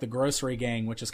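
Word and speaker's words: Grossery Gang: Grocery Gang, but it's spelled G-R-O-S-S, like gross. So Grocery Gang.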